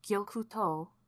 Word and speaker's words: Gyokuto: There's a town in southern Japan called Gyokuto, with like a long O on the end.